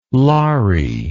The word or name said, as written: laree